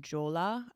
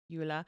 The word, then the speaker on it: Jula: A lot of times it'll go by the name Jola. I don't think I'm pronouncing that correctly, but... – How's it spelled? J-U-L-A? – Yeah. – It's probably Yula. – Yula.